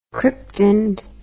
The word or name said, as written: cryptand